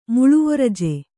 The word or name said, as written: ಮುಳುವೊರಜೆ